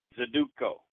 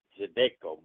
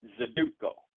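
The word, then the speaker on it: Zydeco: Today's word of the day is Zaduco. Zaduco, and this, I thought it was spelled Zadek, it was pronounced Zadeco, but when I look at the pronunciation, it's Zaduco, okay, it's Z-Y-D-E-C-O, Zaduco, and it's a noun,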